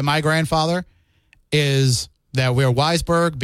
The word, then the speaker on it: Weisberg: The other thing is, I was interested that you said when there's two S's, it's soft pronunciation, and when it's one S, it's like a Z. So your W-E-I-S, your Weisberg. – Yes. – Not Weisberg. Okay.